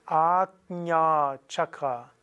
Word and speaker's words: Ajna Chakra: Und es ist Agnya Chakra. Agnya Chakra. Also dieses JN wird so Gnya ausgesprochen.